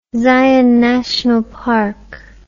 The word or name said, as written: zion national park